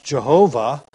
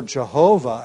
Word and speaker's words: Jehovah: And the Jews were so careful that they would not pronounce the name of God, that they invented a name that gets pronounced Jehovah by taking the tetragrammaton Yahweh, the name for God that he gave Moses at the burning bush, and taking the vowels from the name Adonai, inserting them between the consonants of Yahweh, and inventing a blended name, Yehovah, or Jehovah, as it's pronounced,